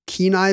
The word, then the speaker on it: Kenai: For many years, the award was not held by, or the record was not held by our gentleman from the Kenai Peninsula.